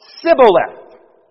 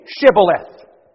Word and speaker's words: Shibboleth: All right, then say this word, Shibboleth. But you see, the Ephraimites couldn't pronounce it that way. The Ephraimites said Sibboleth.